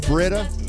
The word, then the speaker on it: Brita: Brita or Brita? How do you spell it? I mean, how do you say it? It's Brita. – Yeah. – Brita. Not like Matt Brita.